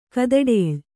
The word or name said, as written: ಕದಡೇಳ್